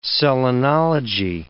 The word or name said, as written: selenology